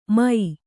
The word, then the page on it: ಮೈ: mai